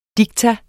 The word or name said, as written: diktum